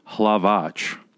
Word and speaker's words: Halavač: In the proper way it's supposed to be pronounced, because of the accents on on some of the letters, it's Halavač.